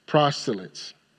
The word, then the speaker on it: proselytes: In fact, if we can put verse 10 of Acts chapter 2 back on the screen, and it says, both Jews and proselytes. I think I'm saying that correctly. Proselytes, thank you, Doc.